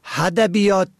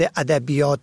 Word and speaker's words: ادبیات: و ادبیات را که با الف شروع می شود با ه هدبیات تلفظ می کنن.